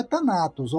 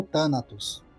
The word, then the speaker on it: Thanatos: O deus da morte é Thanatos ou Thanatos. Depende de quem pronuncia.